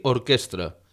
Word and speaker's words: orquestra: Finalment, cal tenir en compte que hem de pronunciar la R de Frederic i orquestra, i que la paraula xarampió va amb una R, no és xarrampió.